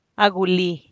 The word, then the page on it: a guli: Teotitlán del Valle Zapotec